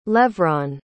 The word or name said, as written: levron